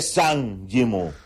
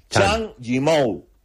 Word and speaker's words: Zhang Yimou: En chino, la Z y la H es Chang. O sea, nada de Zhang Yimou. No, Chang Yimou.